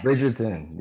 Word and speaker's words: Bridgerton: Bridgerton, is that how you say it? It's the new show on Netflix. – Bridgerton, yes.